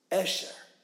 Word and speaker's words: Esher: The first word of the book of Psalms is a Hebrew word, Esher. In English, we would spell it E-S-H-E-R, Esher. Okay? Esher. And there's kind of an A sound to that E in the ancient language.